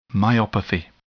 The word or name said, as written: myopathy